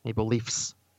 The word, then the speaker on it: Maple Leafs: Pronounce Maple Leafs. Maple Leafs, not Leafs.